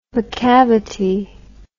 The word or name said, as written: macavity